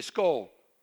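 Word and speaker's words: skål: They say skull. It's spelled S-K-A-A-L.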